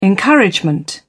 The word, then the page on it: encouragement: British English